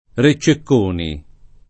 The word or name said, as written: Re Cecconi